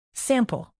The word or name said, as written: sample